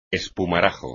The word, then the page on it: espumarajo: espagnol